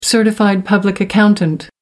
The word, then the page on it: certified public accountant: American English